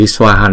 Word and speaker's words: Swaha: Chữ Swaha này chúng ta sẽ đọc hai âm và mỗi âm là hai nhịp.